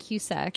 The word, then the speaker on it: Cusack: I don't want to pronounce your last name wrong. Is it Cusack? Is it that simple? – We pronounce it Cusack. – Cusack, okay. – I mean, that's how my family pronounces it. It's Polish.